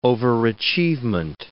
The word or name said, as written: overachievement